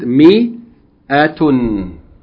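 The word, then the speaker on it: Mi-Atun (مائة): Okay, that the Aleph in Mia is written usually, in some cases, sometimes they don't write it, but it's normally written, but it's not pronounced. Okay, so we pronounce it as though the Aleph is not there. We pronounce it Mi-Atun.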